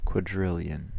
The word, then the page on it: quadrillion: American English; /kwɒˈdɹɪɫjən/